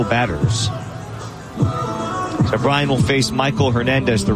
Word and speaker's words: Maikol: Michael Hernandez, who spells his name M-A-I-K-O-L, but pronounced just like Michael.